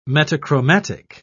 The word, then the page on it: metachromatic: British English